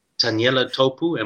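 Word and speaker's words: Taniela Tupou: I used to, when he was still at school and I was still living in South Africa, I watched clips of Taniela Topu.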